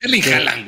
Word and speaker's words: Haaland: ¿Así se pronuncia Holland? – Eh, así lo pronuncia, ya saben. Yo no voy a decir quién. – Holland. Ok. Eh, Holland. – Haaland, güey. – Erling Haaland, güey.